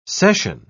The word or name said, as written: session